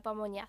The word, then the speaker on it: pamonha: No sé si lo estoy pronunciando bien, se escribe P-A-M-O-N-H-A, pamonha. Viste que tienen como esta pronunciación medio nasal de la ñ al final de la garganta.